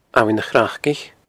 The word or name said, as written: Abhainn a Chraicich